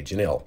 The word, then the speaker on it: Jenille: This first one is from, I believe it's pronounced Janille. J-E-N-I-L-L-E. Janille.